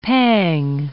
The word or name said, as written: pang